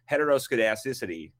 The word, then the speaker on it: heteroscedasticity: and there's something called heteroscedasticity.